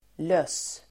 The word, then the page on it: löss: [lös:]